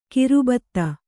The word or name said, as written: ಗರ್ಬಿತ